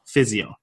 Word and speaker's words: PHZIO: called Physio, and that's spelled P-H-Z-I-O.